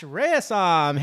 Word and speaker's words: Rayasam: Please welcome to the podcast, Ash Rayasam.